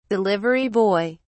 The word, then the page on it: delivery boy: British English